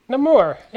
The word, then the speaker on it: Namur: Namur.